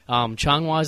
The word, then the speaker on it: Changhua: So I asked him what the Changhua thing was because I'd asked him how to pronounce it, and he said Changhua is the name of the town I lived in while I was teaching English in Taiwan.